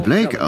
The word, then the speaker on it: Bleeck: Und dann sagte ich, buchstabiert es B-L-E-E-C-K. Sie antworteten, aber das spricht man Blake aus.